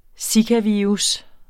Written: zikavirus

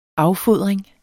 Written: affodring

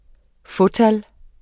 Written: fåtal

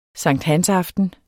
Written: sankthansaften

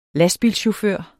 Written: lastbilchauffør

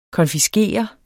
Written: konfiskere

